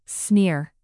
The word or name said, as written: sneer